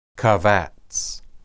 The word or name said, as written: qabats